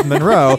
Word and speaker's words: Monroe: He's just, if he's not going to be bothered by the fact that the emphasis is not on the first syllable of Monroe. It's Monroe, but he's like, that's not going to get in my way of my song.